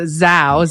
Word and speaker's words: Zao: Yeah, well, first of all, I can't tell you how many times people have spelled it Zoa, Z-O-A. It's a Zao, Z-A-O. I like to say Zao rhymes with wow, because we're just so...